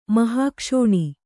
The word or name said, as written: ಮಹಾಕ್ಷೋಣಿ